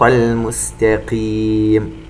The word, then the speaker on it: المستقيم: وهارون، وهديناهما الصراط المستقيم، سبق وأن تكلمنا عن كلمة المستقيم، نركز على أن نتلفظ بالسين سين.